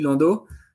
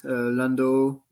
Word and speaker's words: Landot: In French, it's Alexis Landot, but you can say Alexis Landot or Alexis Landot.